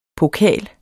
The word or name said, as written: POKALENS